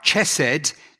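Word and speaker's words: Chesed (חסד): And so the way that you pronounce it, it's not chesed, there's a hard C. Chesed. Can you try saying that with me? Chesed. Again, chesed. Okay, it's not in English, we don't have that hard C, but a lot of you have other languages, your first language, and you can probably say that without any trouble.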